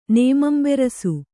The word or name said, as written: ನೇಮಂಬೆರಸು